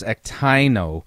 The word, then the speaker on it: ektaino: ektano that Katie Souza says is the definition? – No, you would not. And it's actually pronounced ektaino. And so we're going to note here.